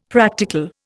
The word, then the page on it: practical: Indian